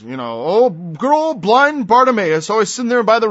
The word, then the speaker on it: Bartimaeus: Blind Bartimaeus. I think I pronounced it wrong. Bartimaeus, the son of Timaeus.